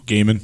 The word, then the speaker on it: Gaiman: Gaiman. – Gaiman.